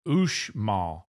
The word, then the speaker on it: Uxmal: And even Uxmal, they pronounce it Ushmal, which is very interesting.